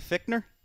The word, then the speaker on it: Fichtner: Randy Fichtner? – Yep.